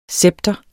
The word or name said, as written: scepter